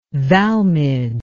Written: Valmid

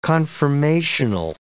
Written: confirmational

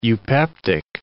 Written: eupeptic

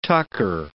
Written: tucker

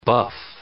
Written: buff

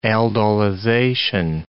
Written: aldolization

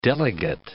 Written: delegate

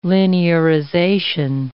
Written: linearization

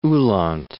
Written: Uhland

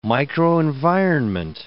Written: microenvironment

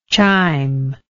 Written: chimb

